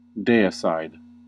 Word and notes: deicide: American English

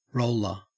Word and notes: roller: Australian English

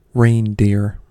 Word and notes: reindeer: American English

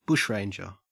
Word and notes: bushranger: Australian English

